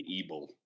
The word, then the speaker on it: Ebel: How do you pronounce it? – Brendan Ebel. – Evil. – Rhymes with evil.